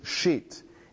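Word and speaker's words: Shit (שית): It's the last three letters of Bereshit, Shin, Yud, Tav. And I just told you how you pronounce that. It's Shit.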